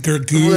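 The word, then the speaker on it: Gergö: It's Gábor, his mom, his dad, and then his brother, Gergo, G-E-R-G-O, with a double dot over the O. And I looked up. – That makes it Gergo. – I looked up how to pronounce it, and it was like Gergo.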